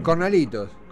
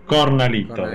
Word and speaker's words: cornalitos: Coralitos, no. – Eh. – Cornalitos. – Cornalitos, gracias, no me salía la palabra.